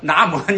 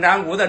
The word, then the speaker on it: 南无: 哎，都是那个除非那个说没学过佛，把南无把那个南无念成南无的那些人，那不算，那不是佛教徒是吧。